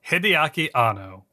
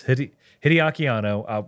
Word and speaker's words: Hideaki Anno: We'll be discussing the works of Hideaki Anno. – Yes, Hideaki Anno.